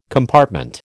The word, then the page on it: compartment: American English